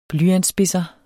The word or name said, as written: blyantsspidser